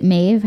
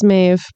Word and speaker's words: Maeve: Yeah, if you didn't know, Stella, is it Maeve? How do you pronounce her? I assumed it was Maeve, but if there's another way. – Nope, we'll just go with that.